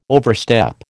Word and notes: overstep: American English

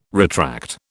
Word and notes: retract: British English

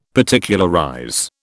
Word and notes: Particularize: British English

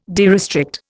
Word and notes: derestrict: Indian

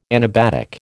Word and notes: anabatic: American English

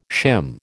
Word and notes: shim: American English

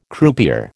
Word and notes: croupier: American English

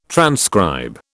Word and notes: transcribe: British English

